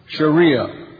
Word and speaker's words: Sharia: Sharia. In the classic, these two dots, in the classic you'll pronounce the T, in modern Arabic you won't.